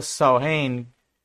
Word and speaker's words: Samhain: Este cambio anual de estación y estilo de vida estuvo marcado por un festival llamado Samhain, o pronunciado Samhain, que rima con vaca, que significa fin de verano.